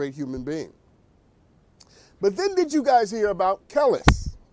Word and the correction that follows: Kelis: Am I pronouncing her name right? Kellis? Yeah. Kelis. Thank you.